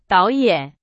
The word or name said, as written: 导演